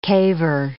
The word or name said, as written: caver